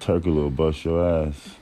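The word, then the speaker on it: Türkoğlu: How do you say Turkoglu.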